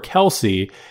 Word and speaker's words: Kelce: But we would honestly go by either one, but I prefer Kelce